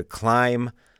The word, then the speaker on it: climb: C-L-I-M-B. C-L-I-M-B. The B is silent. Climb.